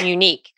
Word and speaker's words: Munique: So if you are familiar with the, am I saying that correctly? Munique? – Munique. M-U-N-I-Q.